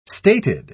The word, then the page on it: stated: British English